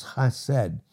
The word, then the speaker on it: chesed: That word mercy in the Hebrew is chesed. It's spelled C-H-E-S-E-D, chesed. That's how it's pronounced.